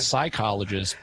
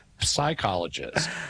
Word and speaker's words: psychologist: So all you got to do is transfer that same sound at the beginning of the word, and it's not psychologist, but psychologist.